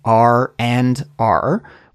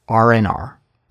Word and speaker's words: R&R: Did you notice how when I said this expression, the D sound in and isn't pronounced? So we don't say R and R. Instead, we smush it together and delete that D sound. So it's just R&R, R&R, R&R.